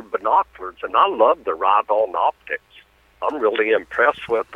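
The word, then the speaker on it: Riton: It's R-I-T-O-N. – Okay. – And it's pronounced Ride-On.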